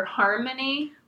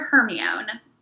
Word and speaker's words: Hermione: A very cute thing about that is, like, his little mispronunciation of her name is, like, a nod to those of us who were reading the books before we knew how to pronounce her name. – And maybe called her Harmony? – I called her Hermione. Everyone said Hermione.